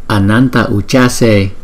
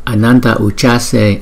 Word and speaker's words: Ananta Ujjase: Danh hiệu số 19 nè, Vô Lượng Cúc Quang Phật chúng ta phát âm là Ananta Ujjase. Cái chữ J này chúng ta đọc là Ch, Ch. Ananta Ujjase, Ananta Ujjase.